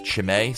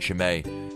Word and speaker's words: Chimay: One, the only one that I can really think of off the top of my head these days is, I hope I'm pronouncing this right, Chimay, C-H-I-M-A-Y. It's like a large, ridiculously large bottle of beer.